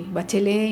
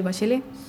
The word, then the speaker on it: Bachelet: Ella es de origen francés, como se puede ver en su nombre, aunque nosotros lo pronunciamos a la chilena el nombre, ¿no? Decimos Michelle Bachelet y no Michelle Bachelet, como debería ser tal vez.